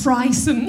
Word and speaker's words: frisson: A frisson, and then we're like... – Is that French? – I don't know. Have I pronounced it wrong? – No, it's frisson.